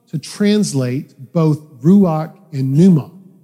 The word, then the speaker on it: pneuma: The word in Greek is pneuma. The P is silent. Say that with me.